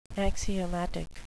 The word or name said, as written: axiomatic